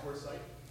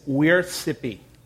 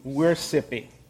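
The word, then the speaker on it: weorþscipe: It is wiersippi. Wiersippi. It is the Anglo-Saxon word where we get worship from.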